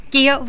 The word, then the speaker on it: Keogh: ¿Y se, pronuncia Kio? – Sí, correctamente. Se raro. – Kio,